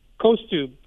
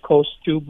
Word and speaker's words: Kostub: Like, the way you would pronounce it is sort of like the coast, like West Coast, Kostub with the B at the end. You know, it's spelled obviously differently, but if you had to spell it the way it sounds, it would be, I guess, Coast-OOB, Kostub.